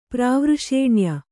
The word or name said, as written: ಪ್ರಾವೃಷೇಣ್ಯ